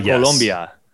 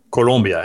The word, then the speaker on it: Colombia: Or did I say it wrong? – Colombia. Colombia. And the food is amazing.